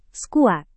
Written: skuad

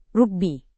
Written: rugbi

